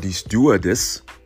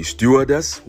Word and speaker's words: Stewardess: Well, you would say die Stewardess. You can put the emphasis on the first syllable. Die Stewardess, which is what I prefer. That's the way I learned it, die Stewardess.